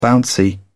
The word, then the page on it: bouncy: British English